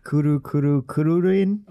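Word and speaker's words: Kuru Kuru Kururin: How would you pronounce that in flawless Japanese? Kuru. In front of the mic, please. – Kuru-kuru-kururin. – Okay, great. There we go!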